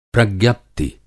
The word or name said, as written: प्रज्ञप्ति